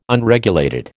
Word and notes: Unregulated: American English

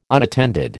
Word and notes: unattended: American English

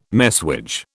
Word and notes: messuage: British English

